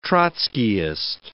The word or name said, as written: Trotskyist